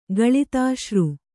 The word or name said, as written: ಗಳಿತಾಶ್ರು